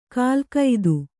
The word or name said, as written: ಕಾಲಗತಿ